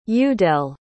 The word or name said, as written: udayle